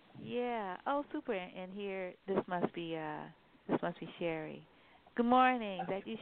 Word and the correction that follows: Cherie: Yeah, her name is pronounced Cherie, I believe, but she'll tell you.